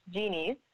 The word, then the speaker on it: Geenees: So Geenees, and is just imagining basically a genie in a bottle that makes wishes come true. And when you have lots of them, they become genies.